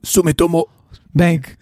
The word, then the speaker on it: Sumitomo: Sumitomo Bank.